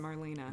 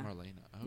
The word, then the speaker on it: Marlene: And it's spelled like Marlene, but it's pronounced Marlena. – Marlena, okay.